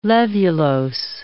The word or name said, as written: levulose